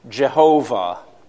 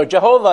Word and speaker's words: Jehovah: So William Tyndale represented YHWH as JHVH. And he brought to us the old theoretical pronunciation of Jehovah, which many people today still use. So Jehovah and Yahweh are different theoretical pronunciations for God's Hebrew name. Either way, it's the same name, just different ways of saying it.